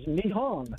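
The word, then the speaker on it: Nihon (日本): And Japan is Nihon.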